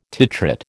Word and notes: titrate: American English

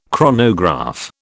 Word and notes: chronograph: British English

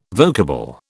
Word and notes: vocable: British English

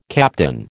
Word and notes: captain: American English

